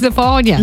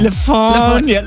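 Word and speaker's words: Le Fanu: It's spelled Lefanu. – Yeah. – But apparently it's Lefania. – Lefania.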